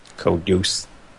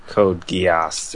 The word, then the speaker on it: Code Geass: Is that really how you pronounce it? – No. – Oh, okay, I was going to say, man, I've been way off base. – Code Geass or whatever.